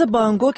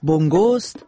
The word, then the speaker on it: بونگو: ساز بانگو که تلفظ صحیحش بونگوست، سازی کوبه‌ایه به شکل طبل کوچیکی پوشیده شده از پوست که معمولاً دوتایی در قابی فلزی قرار میگیره و نواخته میشه.